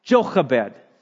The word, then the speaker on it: Jochebed: Or if, as I taught you before in Hebrew, we would pronounce it with a nice sound, Jochebed.